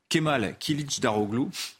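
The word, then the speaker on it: Kemal Kılıçdaroğlu: C'est un homme qui s'appelle Kemal Kilic Daroglu, pardonnez mon, turc, mais j'ai vérifié la prononciation, normalement c'est ça, Kilic surnommé le Gandhi turc.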